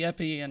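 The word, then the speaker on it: épée: The epee and...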